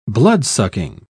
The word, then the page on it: bloodsucking: British English